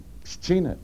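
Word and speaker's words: Pszczyna: P-S-Z, C-Z is psch, and then Y-N-A-E-N-A, it's nothing. Pschina, Poland.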